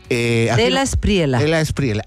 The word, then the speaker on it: De la Espriela: Despriela, ¿así se pronuncia? ¿Despriela? – De la Espriela.